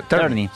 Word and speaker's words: Tierney: ¿Tierney? ¿Cómo se pronuncia? – Tierney. – Sí, ok, Tierney.